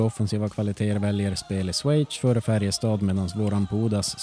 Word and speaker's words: Schweiz: Att du kunde uttala landet Schweiz. Så får du säga efter mig 3 gånger nu. Schweiz. Schweiz. – Schweiz.